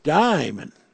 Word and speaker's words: daimon: And then the word daimon, accent on the die, daimon, was another meaning.